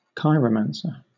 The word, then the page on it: chiromancer: British English